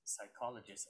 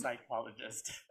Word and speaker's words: psychologist: psychologist. I didn't know that the P was silent, so I said, psychologist. And my teacher was like, this is English Week, the P is silent, it's psychologist.